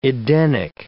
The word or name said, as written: Edenic